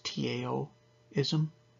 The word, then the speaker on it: Taoism: but more specifically Taoism, which is a weird word because it's Tao-ism, but it's pronounced as if it's a D, it's pronounced Taoism, but it's Taoism spelled.